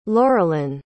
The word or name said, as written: lauralyn